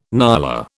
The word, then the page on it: nyala: British English